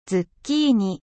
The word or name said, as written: courgette